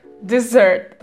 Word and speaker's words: dessert: А вот десерт, desert, произносится как dessert. То есть ударение ставится на второй слог, если слышите, но все равно при этом S произносится как Z.